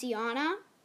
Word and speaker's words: Jana: It's not, like, spelled like Yana, it's spelled like Jana, but it's pronounced Yana.